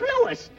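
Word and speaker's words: Louis: Sounds like there might be a story at the plant, Lois. – Lois? Me name is Lewis, not Lois. Gee whiz, everybody interpolates me name wrong. It's Lewis. L? O-U-I-S.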